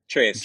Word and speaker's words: Tracz: Please pronounce the name of your book writer. – Trace. – Joe Trace. – Trace. Okay, because it's spelled T-R-A-C-Z and I had no idea.